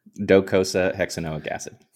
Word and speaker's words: Docosahexaenoic acid: Docosahexaenoic acid.